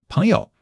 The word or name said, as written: peng2 you5